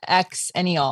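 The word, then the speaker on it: Xennial: I think you, like, well, the X-ennial. I think you mean X-ennial.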